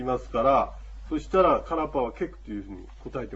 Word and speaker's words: カラッパ: このカラッパというやつもどういうふうなあの文字になるかというと、ローマ字で書きますと、 K-A-R-P-A ですね。 R の発音ですね。 K-A-R-R-P-A。カラッパ。ですから、カーラーパーじゃなくて、カーパーというふうに言うと、これは、あの、発音できます。